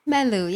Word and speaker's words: Meloo: Meloo, yeah.